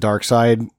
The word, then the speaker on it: Darkseid: It's spelled seed, but I guess they pronounce it Darkseid, especially in the movie.